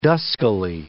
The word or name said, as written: duskily